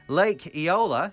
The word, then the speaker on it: Eola: Lake Eola, have I said that right? – Yeah.